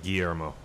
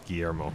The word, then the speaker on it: Guillermo: Guillermo, I believe. – Guillermo? – Guillermo. – Guillermo. All right. And, Martin Bates both liked the podcast, and also I'm going to read, what's his name again? – Guillermo.